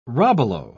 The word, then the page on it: robalo: British English